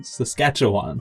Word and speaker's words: Saskatchewan: But we have someone I want to shout out to in Saskatoon, Saskatchewan.